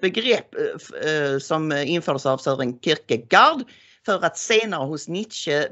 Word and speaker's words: Kierkegaard: Nej, filosofen Søren Kierkegaard. Uttalar jag rätt nu? Gård, Kierkegaard, dansken. Han myntade ett begrepp som kallas för ressentiment.